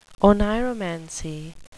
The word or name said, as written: oneiromancy